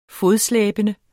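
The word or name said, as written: FODSLÆBENDE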